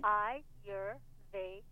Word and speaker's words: Ayurvedic: A-Y is pronounced like I, like your eyes, E-Y-E. – Okay. – Ayurvedic.